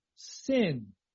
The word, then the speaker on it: Sin: There would be a dot above the right side of this letter that looks like a big W, and that would be pronounced Shin, just like the letter is Shin. And yet, there is also the same consonant, same letter with a dot on the left side at the top. And that's pronounced Sin. Sin. Again, it's more like Sheen and Seen.